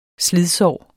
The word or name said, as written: slidsår